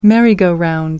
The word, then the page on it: merry go round: American English